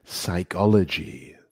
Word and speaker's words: psychology: Y también tenemos otro ejemplo aquí, psychology, psychology. Y empieza con la letra P, that's right. – Pero no se pronuncia, ¿no? – Correcto.